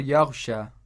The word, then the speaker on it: يغشى: And then we have on the word yaghsha, we have a small alif on top of the ya, and the ya is not pronounced like a ya'ul maddi. It's pronounced like an aliful maddi.